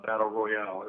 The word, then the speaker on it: Battle Royale: And it's from 2000, it's from Japan, and it's Battle Royal. Or Battle Royale, as some people pronounce it with the E at the end.